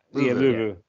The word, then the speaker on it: Louvu: Frankie Louvu, is it Louvu? Is that how to pronounce his name? – Yeah, Louvu.